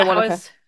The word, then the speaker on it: Wanaka: So you went down for Wanaka. Did I say it right? It's Wanaka, right? – Yes. – Yeah, Wanaka.